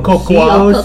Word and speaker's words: cocoa: Pronounce it Coco, right? – Oh, Coco. – Even though it looks like Coco. – Coco. – Cocoa. – C-O-C-O-A. It's Coco.